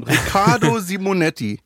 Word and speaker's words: Riccardo Simonetti: Riccardo Simonetti.